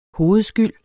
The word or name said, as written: hovedskyld